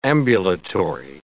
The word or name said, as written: ambulatory